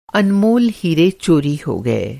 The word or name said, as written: अनमोल